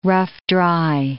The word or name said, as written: rough-dry